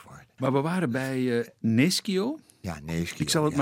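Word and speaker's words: Nescio: Nes Kio. dat is niet goed. Ik hou me dus aan de grammatica. En zeg Neschio, Neschio.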